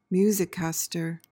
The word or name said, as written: musicaster